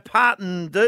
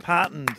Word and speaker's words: partnered: Had six goes at it, and he still can't say it. – Part. – It's two syllables. – Partened.